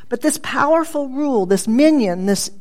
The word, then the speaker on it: minyan: A minyan, the Hebrew word for reckoning. It's a reckoning. And it's spelled M-I-N-Y-A-N.